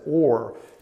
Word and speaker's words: Ur: And sometimes if you saw how some other people pronounce that, sometimes they pronounce it as Or instead of Ur. But I'm just going to go with the way it looks to me. Ur of the Chaldeans.